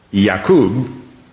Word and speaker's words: Yaqub: And it would be pronounced Yaqub in Hebrew.